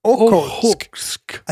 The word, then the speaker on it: Okhotsk: Okhotsk. We were like, we are so much closer to the Sea of Okhotsk.